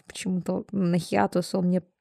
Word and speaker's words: хиатус: Да, у, нас, у нас этот хиатус, хитаус. Мы так и не запомнили это чертово ударение. Хиатус.